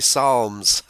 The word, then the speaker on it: Psalms: I can actually hear the L when you say Psalms. Very few people actually pronounce the L.